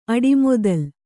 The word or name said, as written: ಅಡಿಮೊದಲ್